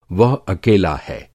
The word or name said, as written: अकेला